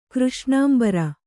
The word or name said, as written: ಕೃಷ್ಣಾಂಬರ